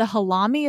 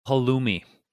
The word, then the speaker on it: Halloumi: The halami? Is that how you pronounce it? Where you can like grill it up. Like you can actually grill the cheese. – Halloumi.